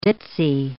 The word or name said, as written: ditsy